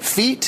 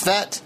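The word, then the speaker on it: Fete: I was pronouncing it feet. Someone told me it's pronounced Fett.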